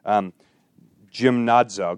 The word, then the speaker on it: gymnazo: And I want you to know that the word behind the word for training is the Greek word gymnazo. Gymnazo is how they pronounce it, but it's just it essentially reads like the word gymnasium.